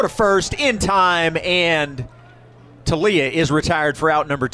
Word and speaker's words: Toglia: His last name is pronounced T-O-G-L-I-A, but the G is silent. Making his way into the batter's box. And the first pitch to Talia, misses for ball one.